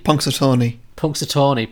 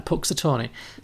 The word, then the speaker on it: Punxsutawney: Punxsutawney. – Punxsutawney, okay. – Did you say it like 2 million times in this film? – Okay, fine, Punxsutawney.